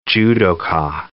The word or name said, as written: judoka